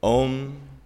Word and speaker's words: Aum: Another interpretation I've heard, if pronounced with all three sounds, Aum. It uses the entire vocal range from the beginning to the end, the whole of creation.